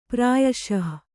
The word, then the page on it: ಪ್ರಾಯಶಃ: Kannada